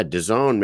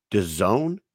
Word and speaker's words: DAZN: DAZN? He's like, I'm not fucking calling it that. There's no way I'm calling it that. It's DAZN. In no language is that pronounced DAZN.